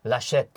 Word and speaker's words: Laschet: 他到底怎么念啊？ – 他叫 Armin Laschet，